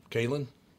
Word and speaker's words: Cailean: Cailean. – You're 0 for 2 so far, Tom. – You guys got way too many. – The E is silent.